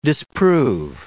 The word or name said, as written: disprove